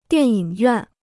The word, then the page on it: 电影院: diàn yǐng yuàn